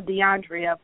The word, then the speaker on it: DeAndrea: Yes, you said it correctly, and I tell people it's spelled just the way it sounds. It sounds just the way it's spelled. My parents were phonetic correct. They just, I don't know, because I'm DeAndrea, but my five brothers are Jonathan, Jeremy, Robert, Marcus, and Walter.